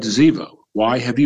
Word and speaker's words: Ziba: Ziba, and in Hebrew, the B has a V sound, so it's Ziba. That's how you would pronounce it. Ziba said to the king, "Behold, he remains in Jerusalem."